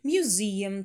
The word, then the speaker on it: museum: Museum. Там у нас и долгий.